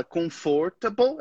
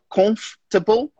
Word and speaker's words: comfortable: Lembrando que a gente não fala comfortable. A gente não pronuncia aquele meio ali, comfortable. A gente fala comfortable.